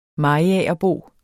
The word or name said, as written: mariagerbo